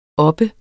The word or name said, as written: oppe